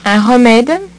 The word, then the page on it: un remede: French